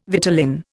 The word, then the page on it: vitellin: Indian